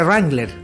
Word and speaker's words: Wrangler: Y en el aviso de Wrangler que estaba recién echando a Chile, él decía: Remember, the W no se pronuncia.